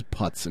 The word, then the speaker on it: Puetz: It's spelled P-U-E-T-Z, golf. That's Putts great. Golf. His last name is Putts, and he decided to open a golf store.